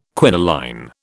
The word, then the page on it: quinoline: British English